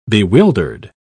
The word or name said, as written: bewildered